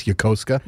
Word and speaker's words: Yokosuka: If you ever see something that looks like Yokosuka, Japan, it's Yokosuka. That's how it's pronounced.